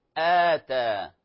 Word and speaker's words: آتى: لاحظوا أتى همزة قطع، نقول أتى، الهمزة التي على السطر وبعدها ألف تنطق كما تلاحظون آتى، مد بدل، وهو من أنواع المد الطبيعي، وقد شرحناه فيما سبق في حلقة ماضية فيما يتعلق في المدود، يرجع إليها جزاكم الله خيرا.